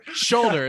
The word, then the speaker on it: shoulder: gives MM like a little soldier or solder. – Shoulder is how you say that word.